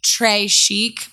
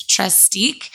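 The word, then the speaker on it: Trestique: Think of it as, like, Trey Chic, but it's Trace Deek.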